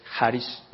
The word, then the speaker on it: charis: La palabra del griego charis, algunos la pronuncian charis en inglés, otros charis, la pronunciación más correcta es charis.